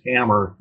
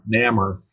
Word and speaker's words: Namor: For Namor number 6, Namor number 6, since I was taught to pronounce it as Hammer, Namor. As a kid, I always slip and try to fall back into that. So it's Namor number 6.